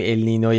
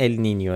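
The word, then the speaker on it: El Niño: بله، به هر حال اینو همین اول بگیم که اگه دیدید یه بار میگیم ال نینو یه بار میگیم ال نینیو، علتش اینه، ولی تلفظ درست اگه میخواین ال نینیو، ولی همه جا تو فارسی به چرخید کسی چیکی نمیفته ال نینیو، همه جا نوشتن ال نینو.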